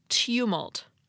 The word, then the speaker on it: tumult: In the tumultuous ongoing saga of how to pronounce the word spelled T-U-M-U-L-T, a couple of weeks ago, I told you that it's pronounced tumult. And that's correct, for American English. But all of you international listeners registered your displeasure because it's pronounced differently in British English. It has more of a Y sound, like tumult, tumult, tumult. So in American English, it's tumult, and in British English, it's tumult, I think.